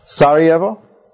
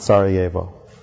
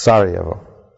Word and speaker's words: Sarajevo: Sarajevo, that's the way it's pronounced, by the way. It's not Sarajevo. It's Sarajevo.